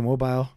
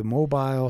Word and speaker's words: Mobile: So he said, and then as soon as he got out of juvie, essentially, he went back to Mobile, and he played for the Mobile Tigers, and Mobile? – He got to play.